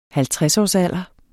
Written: halvtredsårsalderen

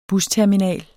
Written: BUSTERMINALENS

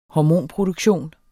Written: hormonproduktion

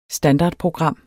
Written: standardprogram

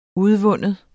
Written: udvinde

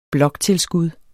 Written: bloktilskud

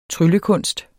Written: tryllekunst

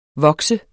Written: VOKSEDES

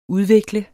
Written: udvikle

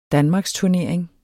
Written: danmarksturnering

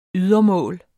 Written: ydermål

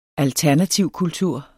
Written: alternativkultur

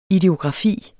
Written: ideografi

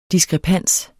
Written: DISKREPANSERNE

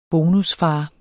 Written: Bonusfar

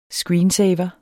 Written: screensaver

